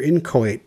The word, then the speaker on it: inchoate: Well, let's start with the pronunciation. We've got two options. – Oh. – Inchoate and inchoate. So neither of which are exactly what you and I were saying. – But, yeah. – Yeah. So you can choose to go all in on the long A there at the end, but in which case they have you emphasizing the first syllable. But anyway, inchoate or inchoate.